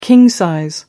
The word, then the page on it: king-sized: British English